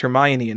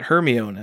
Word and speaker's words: Hermione: Do you know that Hermione's name is pronounced Hermione and not Hermione, as I pronounced it my entire childhood? – Yeah.